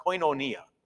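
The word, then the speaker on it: koinonia (κοινωνία): There's one of my favorite words in Greek is a word called, you pronounce it koinonia, means fellowship or mm-hmm.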